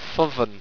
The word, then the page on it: thovn: owstwecian